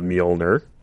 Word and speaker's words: Mjolnir: Well, speaking of Mjolnir, or how is it pronounced? – I think you nailed it.